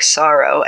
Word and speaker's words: Sahro: Sorrow's name is spelled S-A-H-R-O, but it sounds like Sorrow. And her last name is spelled M-U-S-E, which, is it Muse? – Yeah, Musa or Muse.